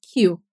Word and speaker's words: queue: Olha a pronúncia da palavra queue. Para você soletrar ela, é Q-U-E-U-E.